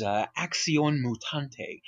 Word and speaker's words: Acción Mutante: This is Acción Mutante. I did that as Caucasian as possible because I'm Mexican, but I wanted to make sure that you knew how to properly pronounce it.